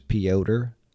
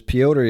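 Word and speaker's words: Petr: It's P-E-T-R. But the way they say it, I think it's Piotr. Don't ask me why. I don't make up the names. It was Piotr Jan versus Aljamain Sterling.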